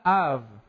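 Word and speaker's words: av (אב): La palabra hebrea para padre es la palabra av. Av. Es A y una B que se pronuncia como V, ¿verdad?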